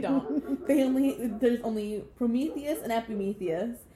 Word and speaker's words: Epimetheus: Epimetheus. Yep, I said it right. Epimetheus.